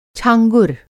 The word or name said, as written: छाँगुर